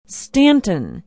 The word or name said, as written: stanton